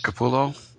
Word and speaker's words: Capullo: But the incentive cover that they have at Midtown Comics by, I think it's Greg, how do you pronounce his name, the artist? – Capullo. – Greg Capullo.